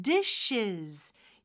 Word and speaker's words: dishes: the 같은 경우는 th 발음 정확하게 해주시고 dishes. 여기서 마지막에 쓰는 z 발음으로 발음해 주셔야 돼요. 그래서 dishes이죠.